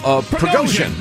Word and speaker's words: Prigozhin: Prigozhin. – There you go. – Yeah. – His name flitted out of my head. He said, if you believe you can trust Russia's promises, ask Prigozhin.